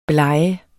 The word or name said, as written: blege